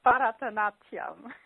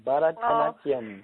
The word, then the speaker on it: Bharatanatyam: Now, for the listeners, how do you pronounce that dance? – It's called Bharatanatyam. – You say it way more eloquently than me. Bharatanatyam.